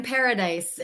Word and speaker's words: Paradis: My name is Caroline Paradis. It's pronounced just like the word paradise. There's just no E at the end.